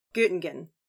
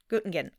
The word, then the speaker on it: Göttingen: G, funny O, T-T-I-N-G-E-N. – Göttingen. Yeah. – Göttingen.